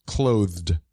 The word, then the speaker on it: clothed: Clothed goes hard, because typically, like, one would pronounce it just closed, as in not open. Mm-hmm. They would sound the same. Clothed.